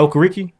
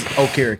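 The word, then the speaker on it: Okereke: It's not O'Kareke. – No, it's O'Kareke. I was listening to him on the radio. They literally said. Like, because I believe niggas were asking, they were like, well, we talked to him, and he said, no, y'all been saying it wrong. – Oh, it is O'Kareke.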